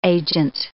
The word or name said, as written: agent